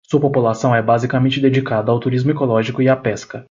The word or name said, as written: basicamente